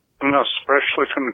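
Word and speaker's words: Unaussprechlichen: I pronounced the name of von Juntz's nefarious tome Unausbrechlichen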